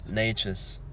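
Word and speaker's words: Nečas: I don't know how to say his name. Nekas. – Natchez.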